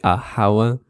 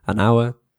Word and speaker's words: hour: But when the H, you know, when the next word starts with an H and the H is silent, we use an as well. So, for example, for the word hour, you say an hour. Be careful, don't say a hour, an hour.